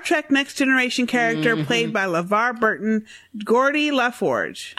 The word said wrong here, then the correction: Geordi: Is it Gordy or Geordi? – Geordi. – Geordi LaForge. Mm-hmm. So you want to pronounce her name with a soft G like germ, gentle, and exactly.